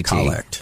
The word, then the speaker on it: collect: It's spelled just like collect, oh, okay. C-O-L-L-E-C-T. – Collect. – But it's pronounced the collect, which you might think, you could think of as the opening prayer.